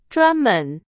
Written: 专门

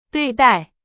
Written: 对待

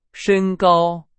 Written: 身高

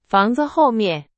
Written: 后面

